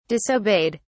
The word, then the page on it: disobeyed: British English